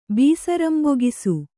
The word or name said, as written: ಬೀಸರಂಬೊಗಿಸು